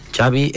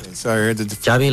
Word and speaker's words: Xavi: Xavi Hernández. – Xavi, okay. – Yeah. Xavi like chocolate.